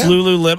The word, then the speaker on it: Lululemon: It's Lululemon. I always want to say Lemon. – Lululemon briefs, but check this out. I just got on their website for three pair from Lululemon.